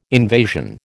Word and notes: invasion: American English

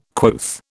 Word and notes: quoth: British English

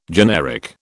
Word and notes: generic: British English

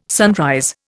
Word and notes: sunrise: Indian

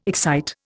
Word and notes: excite: Indian

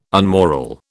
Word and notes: unmoral: British English

